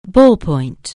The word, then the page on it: ballpoint: anglais